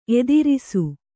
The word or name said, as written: ಎದುರಿಸು